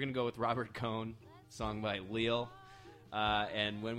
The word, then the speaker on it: Cohn: I think that's how you pronounce it. Cohn. C-O-H-N. – I'd go with Cohn.